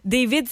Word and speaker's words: D4VD: David, ça s'écrit D4VD.